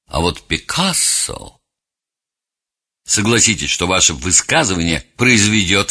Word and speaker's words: Пикассо: Пикассо, но иногда у нас произносят его фамилию как Пикассо, самый плодовитый из всех художников.